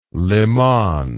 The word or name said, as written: liman